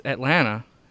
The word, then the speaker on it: Atlanta: And I was always, we had Robert Lanez on, and I was giving him shit because they were calling it Atlanta, and I was like, nah, man, it's Atlanta. Like, you only pronounce that first T, and there's, like, only the last A. It's, like, E-H-T-L-A-N-N-A. That's how you say Atlanta around here.